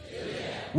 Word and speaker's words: Gilead: Gilead. – With a hard G, say Gilead.